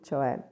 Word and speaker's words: cioè: To me, cioè, in Italian, that means, actually, I can't even express that. So C-I-O-E with an accent on the E, it's like kind of, well, okay.